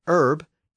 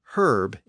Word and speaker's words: herb: Being a French word, the word herb came in with a silent H at the front, and it retained that silent H until the 1800s. But around that time, speakers in Britain started to pronounce a lot of those silent H's, and it became herb in Britain. But American English retained the original silent H, and thus the word is still pronounced as herb in American English.